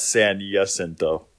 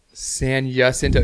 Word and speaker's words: San Jacinto: That's the right name. Wrong pronunciation, though. – What is it? – That's a silent J, buddy. That's San Jacinto. – San Jacinto.